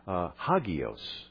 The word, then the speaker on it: hagios: You know the root word, the noun that we pronounced in Greek, hagios, or holy.